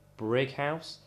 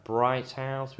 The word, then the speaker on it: Brighouse: The actual like pronunciation is Brighouse, but when people send me stuff, mail, parcels and stuff like that, we've had quite a lot of different names of it. We've had Bright House, we've had Bridge House, we've had Brick House.